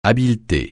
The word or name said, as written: habileté